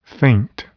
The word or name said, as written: faint